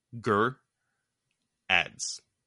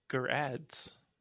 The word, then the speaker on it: Gerads: It's G E R. So so this is the pronunciation. G E R and then A D Z. Ger ads. Ger ads.